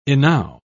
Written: enow

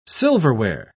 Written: silverware

